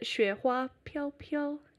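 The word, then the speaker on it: Xue Hua Piao Piao (雪花飘飘): And it's a song called Shui Ha Piao Piao. Did I say that right? – Is it Xue Hua Piao Piao? – Yeah, that's the one.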